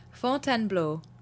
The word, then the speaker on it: Fontainebleau: Fontainebleau. – Bleu. – Bleu. – So phonetically, Fontaine, B-L-O, B-L-O-W, Bleu. – B-L-O-P-N-O.